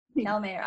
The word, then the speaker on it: Calmeyer: I'm your host, Lisanne Murphy, and I am joined to, before I go, is it Calmeyer? Did I say it right? – Calmeyer. – Okay, I just wanted to make sure. Calmeyer. I apologize.